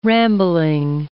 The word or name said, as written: rambling